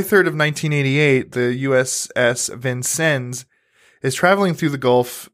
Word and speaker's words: Vincennes: The U.S. Vincennes is the way they say it, right? It kind of looks like it's spelled Vincennes, but the U.S.